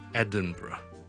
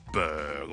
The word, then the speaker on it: Edinburgh: 苏格兰嘅爱丁堡咧，我哋叫做 Edinburgh, E-D-I-N-B-U-R-G-H，一个非常之漂亮嘅城市。咁但系咧，个发音咧系同个串法咧好似唔系好大关系。好多人咧见到 B-U-R-G-H 就会再读成。 Berg 或者 Berg 咁样，咁啊其实唔系嘅，我哋后面嗰咧系一个好简单嘅 bra，bra，bra，bra，bra， so Edinburgh 就唔好读成 Edinburgh 啦。